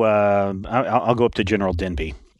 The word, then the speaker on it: Denby: Is it General Genby? – Denby. – Spell that. – D-E-N-B-Y. – D-E-N-B-Y. Yeah. Okay.